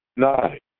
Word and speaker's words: Adonai: It's a difference with that I'm not saying Adonai, because the correct Hebrew pronunciation of Aleph, Dalet, Nun, Yud, Adonai. Nun, Yud, it would be a dot under the Nun as an E, but it's pronounced I, A-I. Adonai.